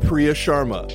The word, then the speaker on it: Priya Sharma: Special interview with Priya Sharma.